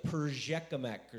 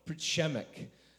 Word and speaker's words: Przemek: The other one was uh, a brother by the name of Przemek, and it took me a while to learn how to say it, as I was like Perzhekamek or something like that. No, no, no, Przemek.